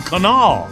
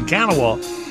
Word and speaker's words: Kanawha: It was the canal. Well, that's anyway. That's the way you pronounce the river that runs through West Virginia. It's a canal. So that hit me. So not the Kanawha.